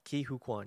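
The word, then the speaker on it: Ke Huy Quan: Ki-Hoo Kwan, yeah.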